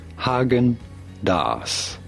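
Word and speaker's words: Häagen-Dazs: Haagen-Dazs.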